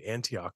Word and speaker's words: Antioch: I hope I'm saying that right. Antioch, right? That sounds right. – It sounds right.